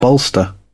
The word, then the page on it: bolster: British English